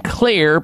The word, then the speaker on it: Xlear: This is the way to zap it, by focusing on keeping your nostrils and your nasal cavities clean and clear by using a safe and natural nasal spray called Clear, spelled with an X on the front end of it, X-L-E-A-R.